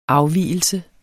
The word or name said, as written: afvigelse